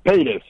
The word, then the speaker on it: Paytas: How do you say her last name again? – Paytas, P-A-Y-T-A-S.